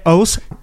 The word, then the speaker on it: Haus: Et eh, que je prononcerai House, H-A-U-S. Ouais, exactement. Je pense que c'est de l'allemand House, comme maison.